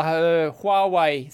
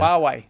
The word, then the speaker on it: Huawei: I usually don't say the H usually. I don't know if that's correct or not. Yeah. – Huawei.